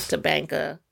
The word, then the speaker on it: Tabanka: And then the other one is Tabanka. Is that Tabanka. – How you say it? T-A-B-A-N-K-A. – I guess.